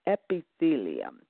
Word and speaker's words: epithelium: Epithelial tissues, and that word is pronounced EP-IH-THEE-LEE-UM. Epithelium.